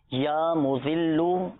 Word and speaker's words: مذل: مذلو اور پہلا جو صفات میں زال کے ساتھ ہی ہے، زال ہے، یا معزو یہ زا کے ساتھ ہے نا، تلفظ کی درستی کا بھی آپ نے خیال رکھنا ہے.